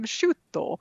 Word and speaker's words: Sciutto: So Jim Sciutto, is that how you say his name? Sciutto.